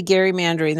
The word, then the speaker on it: gerrymandering: It really should be gerrymandering. The president had it right. Elbridge Gerry, after whom it's named, pronounced his name with a hard G.